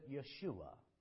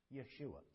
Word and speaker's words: Yeshua: The Jews would pronounce it Yeshua. Yeshua.